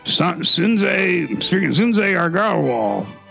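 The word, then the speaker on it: Sanjay Agarwal: Doug, am I freaking pronouncing that right? Or what the heck am I trying to say? – Yeah, can you say it again? – Sunjay Argarwal. – Yeah, that sounds right.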